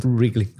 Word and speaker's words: Wrigley: Wrigley, como Wriggle Wriggle.